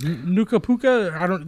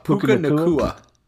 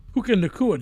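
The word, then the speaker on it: Puka Nacua: What's his Nuka Puka? That's not how you say it, but that's how I like to say it. – Puka Nakua. – Puka Nakua, dude.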